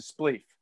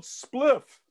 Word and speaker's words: spliff: He's like, that's great, but it's spelled spliff. – I'm like, I know, Dad, but it's, you know, you got to be like Rasta with it, man. You got to be like spleef, man.